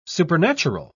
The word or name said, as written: supernatural